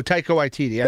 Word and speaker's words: Taika Waititi: But I think Sam Raimi now will do for Doctor Strange what, and I'm not going to say his name right, Takiti Watiki did for Thor. – Oh, Taika Waititi.